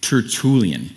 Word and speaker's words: Tertullian: But there was a second century guy named Tertullian.